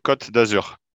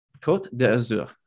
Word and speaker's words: Côte d'Azur: Côte d'Azur. – Côte d'Azur.